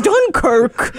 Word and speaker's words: Dunkirk: I haven't been felt this much up river since we've been up Dun Creek. – It's pronounced Dunkirk.